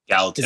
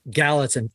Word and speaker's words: Gallatin: It's called the Mighty, and I'm going to say, I hope I don't say this wrong, the Mighty Gallatin 350. – Gallatin. – Is that right? Gallatin. God, I was a 50-50 shot, man. I messed it up.